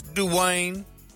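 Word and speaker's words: Duane: Get his name right. It's Dwayne.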